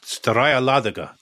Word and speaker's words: Staraya Ladoga: And there's a town, Staraja Ladoga. – Yeah, Staraya Ladoga, Tom. – Is that how you pronounce it? – Your Russian is shocking.